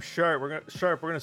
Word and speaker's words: Sharpe: The thing is, Chris, his name is uh, Sharp, but it's spelled S H A R P E.